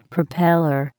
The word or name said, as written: propeller